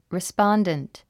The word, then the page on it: respondent: rispάndənt; リスポォンデント